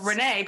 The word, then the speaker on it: Renee: Renee, people love to misspell that, R-E-N-E-E.